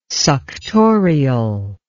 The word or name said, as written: suctorial